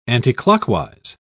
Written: anticlockwise

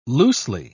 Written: loosely

